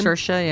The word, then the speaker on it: Saoirse: Saoirse, yeah.